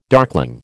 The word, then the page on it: darkling: American English